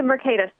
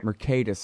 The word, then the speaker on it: Mercatus: And how do you say the name of that center again? – It's the Mercatus Center. – Mercatus Center.